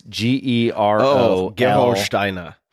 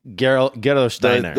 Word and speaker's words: Gerolsteiner: It's, out of Germany and it's called, I'm going to butcher this name, but it's Gerolsteiner. – Gerolsteiner.